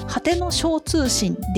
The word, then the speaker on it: 果ての星通信: 正しくは「果ての小通信」でした。星と書いて小と読むんですね。